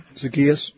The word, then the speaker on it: Zacchaeus: You know, I'll wrap this up, but I was running of Zacchaeus. Is that how you pronounce his name? – Yeah. – Mm-hmm. Zacchaeus or Zacchaeus? – Mm-hmm. Zacchaeus.